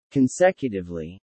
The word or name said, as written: consecutively